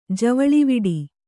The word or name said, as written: ಜವಳಿವಿಡಿ